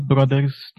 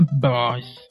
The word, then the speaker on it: Bros.: na verdade é como a gente pronuncia mesmo, né? Não é bros. É, é nos Estados Unidos e na Inglaterra, onde fala inglês, é, é brothers. Só que bros é a abreviatura, né? De brothers.